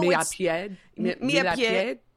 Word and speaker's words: Millepied: And I know Millepied. – Right. It's obviously spelled millipede. And I don't want to – I know it's not pronounced that way.